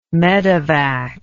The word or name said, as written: medivac